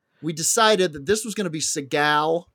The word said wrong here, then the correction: Seagal: Why do I say it like that? That's Seagal. Seagal.